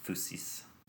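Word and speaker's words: Phusis (φύσις): Phusis is the Greek word for nature, right? – Phusis. Yeah, all those upsilons get changed to y's, and it messes up our English pronunciations.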